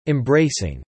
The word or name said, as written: embracing